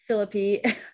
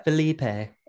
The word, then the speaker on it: Phillippe: But yeah, he, let's he talk about a lot of this about Ryan Phillippe, who Felipe. – Stop. I thought my sister told me that that's how it was pronounced, and until this year, I pronounced it like that.